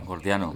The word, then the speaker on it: Gordiano: al nudo Jordiano, ¿lo he dicho bien? Jordiano. – Gordiano.